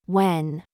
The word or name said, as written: DRESS